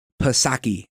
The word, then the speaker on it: Psaki: And I want you to hear a little bit from Jen Psaki. And I know the P is silent.